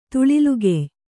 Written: ತುಳಿಲ್ಗೆಯ್

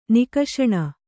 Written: ನುಕಸಾನು

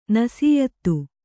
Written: ನಿಸ್ಯೂತ

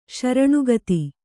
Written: ಶರಣಾಗತಿ

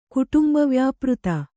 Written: ಕುಟುಂಬವ್ಯಾಪೃತ

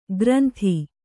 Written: ಗ್ರಂಥಿ

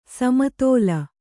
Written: ಸಮತಳ

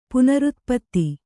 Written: ಪುನರುತ್ಪತ್ತಿ

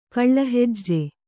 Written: ಕಳ್ಳಹೆಜ್ಜೆ

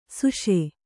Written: ಸುಷೆ

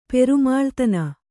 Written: ಪೆರುಮಾಳ್ತನ

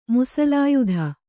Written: ಮಸಲಿಯತು

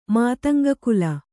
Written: ಮಾತಂಗಕುಲ

